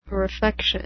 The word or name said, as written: refection